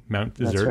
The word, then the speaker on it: Mount Desert: I always say that wrong. Mount Desert. – That's right.